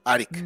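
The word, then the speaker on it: Airk: ¿Se, escribe Ayrk? pero yo escucho que lo pronuncia como. como. – Arik, ¿no? – Es como Arik. – Arik. Mm. Ok.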